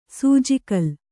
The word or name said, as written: ಸೂಜಿಕಲ್ಲು